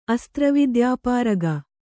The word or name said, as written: ಅಸ್ತ್ರವಿದ್ಯಾಪಾರಗ